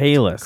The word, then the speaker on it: Chehalis: Chehalis.